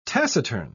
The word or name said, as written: taciturn